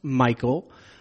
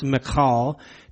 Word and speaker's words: Michal: All right, first of all, the name Michal, that is the feminine version of Michael, and it really should not be pronounced Michael. I've heard people do that. They'll take this name here in 1 Samuel 21, and they'll pronounce it Michael. I've probably even done that before, but it actually is pronounced Michal.